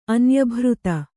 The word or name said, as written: ಅನ್ಯಭೃತ